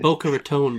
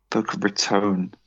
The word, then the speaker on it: Boca Raton: Boca Raton Bowl. – Boca Raton. So I've got to overpronounce the O's.